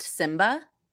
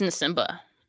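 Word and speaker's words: Nsimba: What is it just pronounced Simba? – I think it's Nasumba. – Because on the broadcast, Greg Papa kept calling him Simba. And I was like, oh my God, is the N silent? So I'm going to have to look into that.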